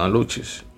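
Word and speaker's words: aluxes: Porque según yo leí, los aluches o aluces, bueno, aluches, Pasa que es con X. Pero no, la, ustedes saben que la X se pronuncia como CH, aluches.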